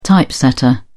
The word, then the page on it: typesetter: British English